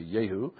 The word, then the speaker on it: Jehu: The J in Hebrew is pronounced as a Y, so this guy is a Jehu.